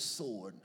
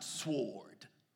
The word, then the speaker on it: sword: And sharper than any two-edged sword. Not sword. Sword. Said with me, sword. Just like the L and salmon. And salmon is silent.